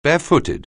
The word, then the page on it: barefooted: anglais